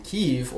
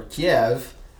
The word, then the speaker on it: Kyiv: And Kyiv, or Kiev, which it's sometimes pronounced, was the capital of the Kievan Rus people and is the capital of modern-day Ukraine.